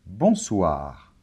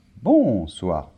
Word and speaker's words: bonsoir: Bonsoir. Très bien. L'accent tonique est toujours à la fin des mots en français. Donc, ce n'est pas bonsoir, non. Bonsoir.